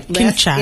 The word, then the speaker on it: Kinshasa: And in 2015, she started a home for street kids in Kinshasa, Congo, with her nonprofit. I hope I pronounce this right. – Kinshasa.